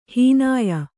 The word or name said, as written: ಹನೋಯು